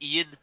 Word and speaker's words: Iain: Oh, no, it's just Ian. In Scotland, like, you'll often see Ian spell I-A-I-N.